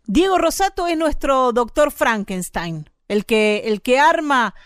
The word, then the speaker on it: Frankenstein: Este, eh, Frankenstein se pronuncia, pronuncia como lo hiciste vos, Frankenstein.